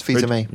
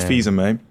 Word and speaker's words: Fils-Aimé: Ames. – Fils-Aimé. – And me. – It's Fils-Aimé. – Reggie Fils-Aimé.